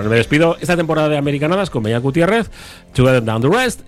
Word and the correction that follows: Tougher than the rest: es. No, tougher. – Tougher than the rest.